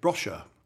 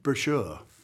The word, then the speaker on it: brochure: Funny enough, in one of the episodes, we had a big conversation with when Hyacinth was going on holiday somewhere, and she pronounced it brosher. – Yes. – Instead of brochure.